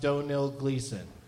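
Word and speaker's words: Domhnall Gleeson: And I've been told Donil Gleason is pronounced Donil Gleason.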